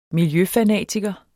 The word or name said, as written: miljøfanatiker